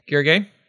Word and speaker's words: Gergely: Gergely.